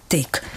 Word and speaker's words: tik: Jen se k tomu píše, když si najdeme to pravidlo, tak najdeme správná výslovnost, od jak živa uváděná, je tvrdě tyk. A k tomu je vždycky dovětek, běžně se ovšem slovo vyslovuje měkce.